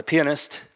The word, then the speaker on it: Pianist: It's actually pronounced The Pianist.